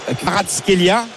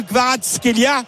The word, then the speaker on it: Kvaratskhelia: Et on va avoir un coup franc effectivement dangereux avec justement Kvaratskhelia. Vaut mieux le dire doucement que vite.